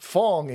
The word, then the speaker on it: Fang: Fang is pronounced Fong in Cantonese, Hong or Pang Pui in Minnan dialects, or you got me all over the place.